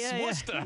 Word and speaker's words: Worcester: And in the place that you say, Massachusetts, I was in Worcester, Massachusetts. – Oh, you said it correctly. Worcester.